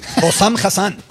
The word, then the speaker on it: Hossam Hassan: Hossam Hassan.